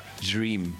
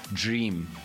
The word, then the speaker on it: Jream: But they spell it with a J. Dus, wat? – Ja, dream. – Waar is die? – Not dream, dream. So it's a J. – Spel hem? – J-R-E-A-M. Like dream, maar net dream.